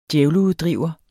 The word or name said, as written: djævleuddriver